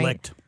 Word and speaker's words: Licht: CEO, Chris Licht, I hope I'm saying his name right. – Licht. – Yeah. Licht. That's he's been licked.